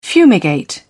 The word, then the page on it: fumigate: British English